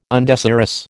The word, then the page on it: undesirous: American English